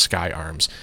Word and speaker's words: SCCY: I think it's SCCY, and I'm pretty sure it's pronounced Sky Arms.